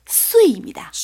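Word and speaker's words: 쇠입니다 (岁): 우리 항상 깨지다 중국 발음은 쇠입니다.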